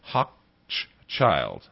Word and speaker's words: Hochschild: How do you pronounce Hochschild? Well, Hochschild.